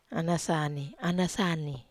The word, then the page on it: anasani: Wamesa